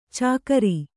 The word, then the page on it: ಚಾಕರಿ: cākari